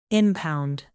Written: impound

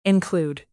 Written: include

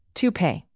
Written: toupee

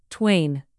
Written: twain

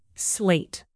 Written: slate

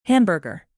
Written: hamburger

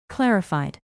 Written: clarified

